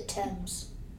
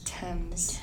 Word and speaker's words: Thames: The Thames. – Is that how you pronounce it? – It is. – Thames. – Thames. – It's the Thames.